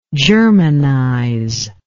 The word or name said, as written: germanizer